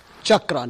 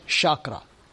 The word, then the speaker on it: chakra: So that's, now in terms of the pronunciation, you know, the word is chakra, not chakra. It is C-H. Like in a child, chakra. Okay, so try not to pronounce it as chakra. It's not S-H, it's C-H. Okay, just one of those things. Because I hear many people pronounce it as chakra. That's not what it is.